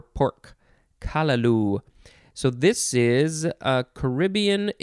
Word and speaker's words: callaloo: Let's see, kalaloo, or you could emphasize the first syllable, which would be kalaloo.